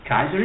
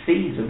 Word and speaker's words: Caesar: Kaiser is Caesar. The Romans present, we pronounce it Caesar, but the Romans pronounce Kaiser.